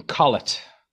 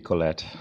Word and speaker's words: Collett: Nicholas is very English, so he would tell everyone to pronounce his name Collett. – Thank you. – Even though it has French origins, which would be Colette.